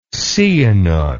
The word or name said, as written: Sihanouk